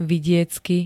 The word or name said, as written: vidiecky